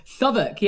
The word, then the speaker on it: Southwark: Southwark, yeah.